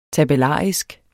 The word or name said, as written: tabellarisk